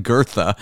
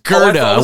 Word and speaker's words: Gerda: Kind of sounds like Gertha. – Cheese. – But just Gerda with a hard G.